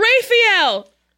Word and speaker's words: Raphael: It's June, Diane, Raphael. I said it correctly. – You did.